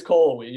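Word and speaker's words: Cullowhee: I think it's Colwee.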